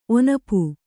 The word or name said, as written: ಒನಪು